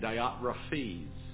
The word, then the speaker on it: Diotrephes: You're supposed to say it Diotrephes, and it doesn't sound right, but that's the correct pronunciation, so I say it the wrong way.